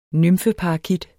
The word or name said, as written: nymfeparakit